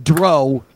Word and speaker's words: drow: Actually, drow is the older pronunciation of it, because it originally was a, I think, Norse or Celtic. One of the older mythologies had that name, and it was pronounced drow.